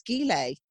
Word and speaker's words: gilet: No, she said, it's a hard G, it's gilet.